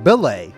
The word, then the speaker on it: belay: And I discovered that there's actually a word called belay, with a B, not a D. He's saying belay that order, not delay that order.